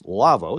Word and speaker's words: Lawo: Our show is brought to you in part by the folks at Lavvo, L-A-W-O, pronounced Lavvo.